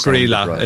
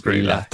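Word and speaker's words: Grýla: It is Grilla. It is Grilla. That's the only one I know the pronunciation for.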